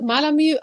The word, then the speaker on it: Malamü: Ja, äh, ja, mein Label heißt Malamü. Auf Instagram ist es Malamur, deshalb ist das manchmal mit der Aussprache auch ein bisschen schwierig.